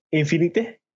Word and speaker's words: Infinite: Hablamos de Halo Infinity. Infinite. ¿Cómo se pronuncia? Infinite. – Bueno, más porque, Infinite. Porque es diferente la palabra.